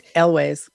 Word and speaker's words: Elwes: Is it Elwes or Elwes? – Elwes.